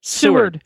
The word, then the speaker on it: Seward: And then we're catching a cruise, a 7-night cruise on Royal Caribbean from Seward. How do you say that? I don't know. – Seward, Alaska, Seward. And then Seward.